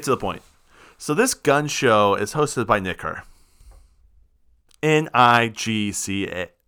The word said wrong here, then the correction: NIGCA: Nicka is what it is. There's no R. I'm so sorry. No R. I butchered this.